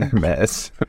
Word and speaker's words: Hermes: Hermes. No, yeah. No, you said it right.